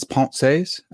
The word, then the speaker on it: Pensées: I know that it's Pensées. I had Guillaume Bignon on to tell me that.